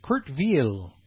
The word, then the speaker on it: Kurt Weill: And Kurt Weill, I'm being told, is how that's pronounced. It's a German.